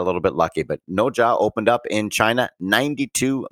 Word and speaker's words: Nezha: And Noja, very happy because it's spelled N-E-Z-H-A.